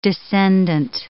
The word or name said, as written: descendent